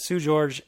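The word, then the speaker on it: Seu Jorge: But, that's, it's pronounced Sue, S-E-W, but it's spelled S-E-U. And George spelled like Jorge, J-O-R-G-E. But Sue George is he's from Brazil.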